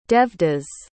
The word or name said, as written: Devdas